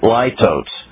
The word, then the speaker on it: litotes: But when it says, therefore, when Paul and Barnabas had no small dissension and dispute with them, I think you pronounce it litotes. All right? But I've never heard anybody else pronounce it. L-I-T-O-T-E-S.